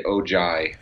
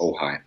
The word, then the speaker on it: Ojai: As long as you don't say Ojai, we'll run you out of town if you do that. But it's definitely an unusual name. So yeah, Ojai, right? – That's right, Ojai, and it's pronounced Ojai.